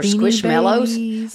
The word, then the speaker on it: Squishmallow: For Squishmallows? Are they? Am I saying it right? Squishmallow? – Yeah. – Squishmallow. – Yeah. – So, guys, look. – It's like a marshmallow.